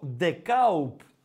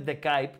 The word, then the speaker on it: Ντεκάουπ: Ε, ε, θα ήθελα, λέει, να είμαι στο Ντεκάουπ. Αυτή είναι η σωστή. Έτσι διαβάζεται. Δεν είναι Ντεκάιπ που το λέμε εμεί. Το σωστό είναι Ντεκάουπ. Το έγραψε πολύ σωστά ο Βασιλάκο και με έπιασε εξαπίνη.